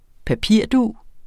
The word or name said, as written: PAPIRDUG